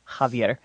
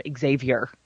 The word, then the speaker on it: Javier: He's Spanish, I know, but there are people who aren't Spanish named Javier and called Xavier, which, I mean, if you're going to mispronounce a name, Xavier, obviously, the go-to fuck up.